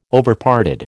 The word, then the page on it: Overparted: American English